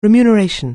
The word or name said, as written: remuneration